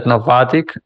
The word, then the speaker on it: Navattic: So now that you are leading growth and operations at Navattic, and I hope that I pronounced it correctly.